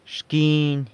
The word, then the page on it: xquiny: corner